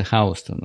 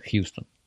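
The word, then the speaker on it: Houston: Город Хаустон и улица Хаустон пишутся одинаково по буквам, но здесь она читается Хаустон, а город называется Хьюстон.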